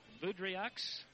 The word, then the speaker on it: Boudreaux: Carlson Boudreaux, spelled with the X. B-O-U-D-R-E-A-U-X. Boudreaux, Boudreaux, Boudreaux, however you want to pronounce it. It's Boudreaux. Fulmer gets the basket, has a chance for the three-point play.